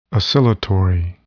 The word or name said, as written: oscillatory